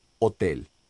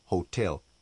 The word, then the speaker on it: hotel: Hotel. La H de hotel es más suave que la J de jabón. La E de la segunda sílaba lleva el acento tónico y se pronuncia E.